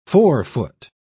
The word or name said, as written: forefoot